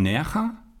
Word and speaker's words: Nerja: Und dann gibt es die Höhlen von Nerja.